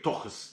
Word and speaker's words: тухес: Некоторые говорят тохес, некоторые говорят тухес. Значит, мы говорим тухес.